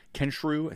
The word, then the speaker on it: Kensrue: Kensrue, I think is how you say his name. K-E-N-S-R-U-E.